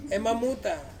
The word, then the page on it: mamuta: Ansus